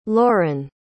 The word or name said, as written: Lorren